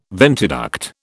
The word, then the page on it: ventiduct: British English